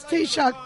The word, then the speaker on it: Taoiseach: It's Taoiseach, people. So, you know, be a sport. Say it right instead of calling in the prime minister.